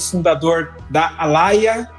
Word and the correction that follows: Alia: Falei certo, Marco? – Falou errado. É Alia.